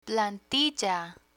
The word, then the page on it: plantilla: spanish